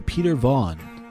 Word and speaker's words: Peter Vaughn: My own goes to Peter Vaughn.